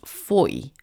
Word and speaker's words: Fowey: So we stayed in the most beautiful little cottage in a place called Lostwithiel, which is outside of what is spelt Fowey, but actually by the locals pronounced Foy, as in joy.